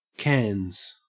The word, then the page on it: cans: Australian English